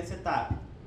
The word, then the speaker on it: setup: que é como o DOSVox pronuncia, mas a pronúncia correta seria setup.